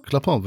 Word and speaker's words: Clapin: Clapin, würde ich sagen. Ja, hast du sehr schön ausgesprochen.